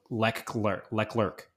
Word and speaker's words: Leclerc: The one thing that just frustrates me is that everyone pronounces his name wrong. It's Leclerc.